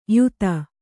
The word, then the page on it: ಯುತ: Kannada